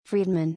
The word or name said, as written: M. Friedman